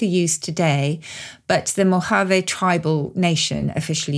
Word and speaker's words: Mojave: Is it Mojave? Is that how you pronounce the name of the desert? – Yes, I think so, because it's from Spanish.